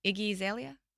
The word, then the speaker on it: Iggy Azalea: Iggy Azalea? – Oh, yeah.